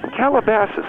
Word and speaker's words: Calabasas: Calabasas.